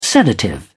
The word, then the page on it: sedative: British English